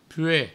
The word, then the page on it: pueg: pue; provençal